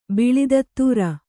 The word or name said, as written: ಬಲೂತಿದಾರ